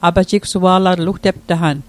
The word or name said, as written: apajigsua'latl